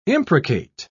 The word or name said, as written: imprecate